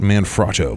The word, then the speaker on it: Manfrotto: Manfrotto, made in Italy.